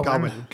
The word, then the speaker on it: Gawain: So as you mentioned, Gawain, how am I pronouncing this? – I've heard it pronounced several different ways. It's like Ga and then Win, Gawain. – Gawain, okay.